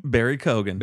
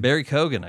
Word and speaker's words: Barry Keoghan: How did we say this guy's name? Barry Keoghan? – Barry Keoghan. – Barry Keoghan, I got it.